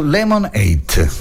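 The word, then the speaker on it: Lemon8: Sì, perché molti utenti americani stanno lasciando TikTok e stanno migrando su Lemonade. Scritto Lemon 8. Lemonade si pronuncia quasi come lemonade, che vuol dire limonata.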